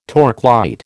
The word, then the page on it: torchlight: American English